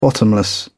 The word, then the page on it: bottomless: British English